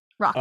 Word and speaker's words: Rokit: It's spelled R-O-K-I-T. Rocket. – Oh. Not even E-T? Just I... – Rocket.